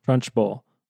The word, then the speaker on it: Trunchbull: How do you say her name? – Trunchbull? – Trunchbull.